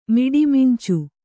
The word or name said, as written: ಮಿಡಿಮಿಂಚು